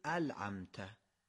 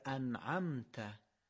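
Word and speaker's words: أنعمت: بعضی وقتا شاید ما دقت نکنیم به جای نون لام تلفظ کنیم، یعنی به جای انعمت بگیم العمت. حالا شما بگید کدوم صحیحه، العمت یا انعمت؟ بله دومی درسته.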